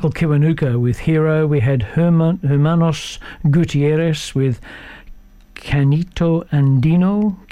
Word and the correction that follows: Canto Andino: Canto Andino. My pronunciation tonight's way off.